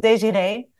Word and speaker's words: Desirée: In the Netherlands, they pronounce it Desiree. But here, Desiree. Everybody says Desiree.